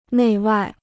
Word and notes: 内外: nèi wài

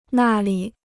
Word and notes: 那里: nà li

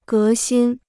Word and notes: 革新: gé xīn

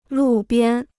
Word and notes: 路边: lù biān

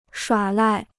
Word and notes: 耍赖: shuǎ lài

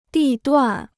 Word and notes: 地段: dì duàn

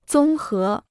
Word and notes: 综合: zōng hé

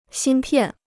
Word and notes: 芯片: xīn piàn

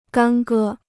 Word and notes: 干戈: gān gē

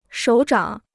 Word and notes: 手掌: shǒu zhǎng

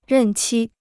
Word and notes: 任期: rèn qī

